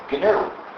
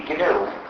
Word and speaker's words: GNU: However, when it's the name of our system, please do not follow the dictionary. Please pronounce it GNU.